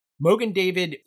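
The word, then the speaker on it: Mogen David: Mogan David is a company based out of Westfield, New York, and it's the Yiddish pronunciation of the Hebrew Magen David, which translates to Shield of David, which refers to the Star of David.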